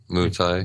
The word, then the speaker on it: Muay Thai: Am I saying that right? – Muay Thai.